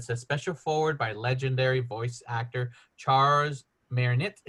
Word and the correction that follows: Martinet: Did I pronounce Charles Martinet. Charles Martinet. Martinet. Martinet.